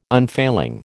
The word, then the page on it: unfailing: American English